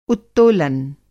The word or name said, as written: उत्तोलन